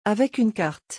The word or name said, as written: avec une carte